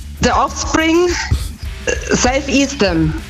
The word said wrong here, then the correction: Self Esteem: Ja, die Aussprache ist ein bisschen... – Team is Team. – Ja. Ja, ja, ist richtig. – Ja, Self Esteem nehmen wir auch.